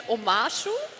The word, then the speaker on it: Omashu: Nein, die richtige Antwort ist, ich kann es auch nicht aussprechen, Omashu? – Das ist richtig.